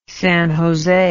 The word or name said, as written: San Jose